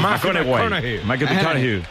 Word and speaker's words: McConaughey: Mateo McConaughey. – Sí, sí, sí, sí, sí, claro. Un futbolero, le gusta. Hay que ir corrigiendo la pronunciación. – McConaughey.